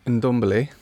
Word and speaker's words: Ndombele: First of all, you can say his name better than me, obviously, because you're not me. – Ndombele. – There it is.